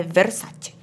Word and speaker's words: Versace: Pra mim, Donatella Versace. Que ela não gosta que pronuncie errado. É Versace. – Versace. – Não, porque eles falam Versace. E ela fica, não, é Versace.